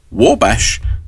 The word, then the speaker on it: Wabash: That was Roy Acuff there with the Wabash.